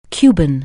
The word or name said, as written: Cuban